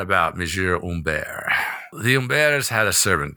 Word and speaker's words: Humbert: That's Humbert pronounced, but spelled Humbert in English, but pronounced Humbert in French.